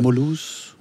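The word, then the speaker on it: Mulhouse: der hedder Molhaus i Alsace. Eller det er jo Frankrig, Molhaus. Altså, Moulouse. – Moulouse. Moulouse.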